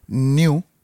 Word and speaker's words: nu: Hay otra letra que, que, le dicen new, que se escribe NU, y que ese iba a ser el nombre de esta nueva variante.